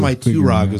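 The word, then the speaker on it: Maentwrog: How do you say the name? – Yeah. – I think it was Maiturog is the way they pronounce it. Yeah. The Maiturog is the way it's pronounced in the audiobook.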